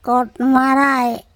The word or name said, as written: koTmaray